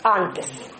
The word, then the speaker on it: Антес: И поскольку он швед по происхождению, то, конечно, фамилия была с ударением на первый слог. Антес.